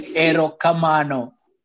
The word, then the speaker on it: erokamano: You say erokamano.